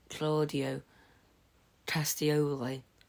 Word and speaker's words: Claudio Castagnoli: Yeah, but anyways, so a non-Ring of Honor star challenging Claudio Castagnoli.